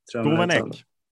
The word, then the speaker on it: Gouvenech: Hur är nye tränaren, eller då för dig nye tränaren också, Gouvenech, om jag är ute, eller hur uttalar man det? – Gouvenech.